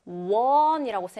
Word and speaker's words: one: 분명 생긴 건 오, 모음인데 one이라고 선생님 발음해서 one. 무슨 발음이에요? W 발음입니다. 그래서 아, 얘도 W로 자음 발음이에요라고 해서 a one way street가 되는 겁니다.